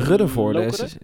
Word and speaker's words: Ruddervoorde: Ruddervoorde, zeg ik dat goed? – Ja, denk het.